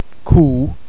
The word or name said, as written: cú